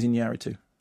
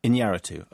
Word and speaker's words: Iñárritu: Alejandro González Iñárritu. – Iñárritu. OK, that's relevant for this email. – Oh, and somebody wrote, and it's not Alejandro, it's Alejandro with a H-y sound. Sorry. – Iñárritu.